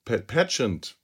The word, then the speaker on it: Pageant: Pageant, Pageant, Pageant spricht man das aus.